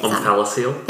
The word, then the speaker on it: omphalocele: Omphalocele.